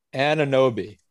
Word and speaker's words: Anunoby: Anunoby.